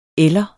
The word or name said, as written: eller